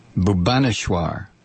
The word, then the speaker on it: Bhubaneswar: Bhubaneswar. That would probably be a better pronunciation.